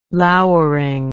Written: louring

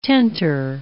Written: tenter

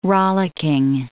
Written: rollicking

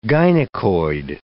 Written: gynecoid

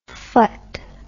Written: phut

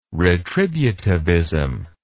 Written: retributivism

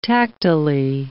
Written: tactilely

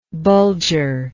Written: Bulger

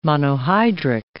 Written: monohydric

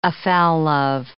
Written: afoul of

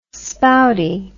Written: spouty

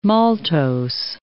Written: maltose

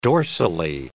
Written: dorsally

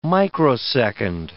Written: microsecond

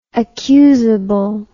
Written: accusable

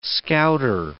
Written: scouter

